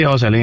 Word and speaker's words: Joselín: Joselín Ramos, o como quieres que, cómo se dice, ¿verdad? Es Joselín, ¿verdad? – Sí, Joselín, ese es el nombre correcto, sí.